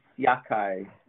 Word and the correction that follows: Yachay: Yachay. – Yachay. That's what I get wrong.